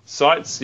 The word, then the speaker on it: Seitz: Denouncing the demonization of migrants, hateful rhetoric, the militarization of the border, and a system that divides family, Bishop Mark Seitz, how do you pronounce that one? – Seitz, probably. – Seitz, yeah, that'd be right, the one I didn't use, of El Paso in Texas, called on Catholics to heed the Church's teaching to welcome the migrant.